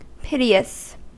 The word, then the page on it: piteous: American English